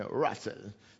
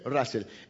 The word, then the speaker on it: Russell: Oh, mira cómo lo pronuncié, Russell. Se escribe Russell. ¿eh? Russell.